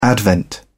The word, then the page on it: advent: British English